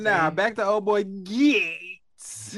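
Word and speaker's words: Gaetz: How you pronounce it? – Gates. That's how you gotta say it.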